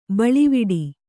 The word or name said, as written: ಬಳುವಟ್ಟೆ